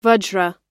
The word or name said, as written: vajra